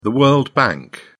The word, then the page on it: world bank: British English